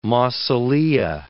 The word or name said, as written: mausolea